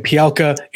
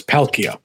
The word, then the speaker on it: Palkia: I was saying Pialka, it's Palkia. – Yeah, it's Dialga and Palkia.